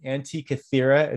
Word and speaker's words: Antikythera: So for those that don't know, the Antikythera, it's spelled A-N-T-I-K-Y-T-H-E-R-A, Antikythera mechanism.